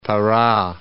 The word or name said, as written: Para